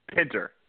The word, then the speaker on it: Pinter: And uh, actually, I didn't even ask you, is it Pinter or Pinter? – Pinter. – Pinter, I was right. – Yes. Yay. Okay. You were right. You were right. It's like uh, spelled like pronounced like Harold Pinter, but no relation.